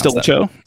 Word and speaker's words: Stilicho: Stilicho.